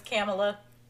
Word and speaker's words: Kamala: No, I hate when people say her name is Kamala. – I hate that. – She is not a camel. Her name is Kamala.